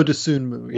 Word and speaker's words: Dosunmu: You know, if Kyle Ayers does not make two bad plays down the stretch and Ayo Dosunmu, is that how you, I never get his name right, Dosunmu. – Ayo Dosunmu, yeah.